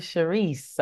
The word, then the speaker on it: Sharis: Nadia Sharis. I had to put the little accent on the end of it.